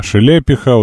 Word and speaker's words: Шелепиха: А Шелепиха, ударение на И.